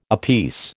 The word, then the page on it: apiece: American English